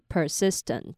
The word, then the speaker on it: persistent: 那接下来是 persistent 这个字，它不太难念，只要念成把它拆成 persist 跟 tent 这三个音节就好了，所以是 persistent。